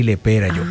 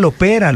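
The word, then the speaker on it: Le Pera: Entonces, Gardel y Lepera. Ay, no era Lopera, lo he dicho mal toda la vida.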